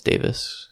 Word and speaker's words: Davies: Davies instead of Davis, I think it's pronounced Davis. – Oh, really? – I think that's what I've heard. – Sorry for interrupting. – Just from his accent. But yeah, it is spelled Davies.